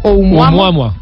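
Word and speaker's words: Oumuamua (ʻOumuamua): Oumuamua.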